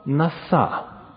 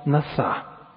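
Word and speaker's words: наса (נשא): Это древнееврейское слово «наса». Ударение на последний слог. Древнееврейское слово «наса».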